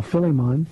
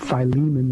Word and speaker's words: Philemon: The little treasure chest of, we call Philemon. Most people call it Philemon. That's just the wrong pronunciation.